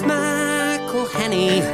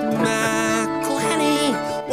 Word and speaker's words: McElhenney: It's McElhenney, McElhenney. If there was doubt, now there won't be any. It's McElhenney.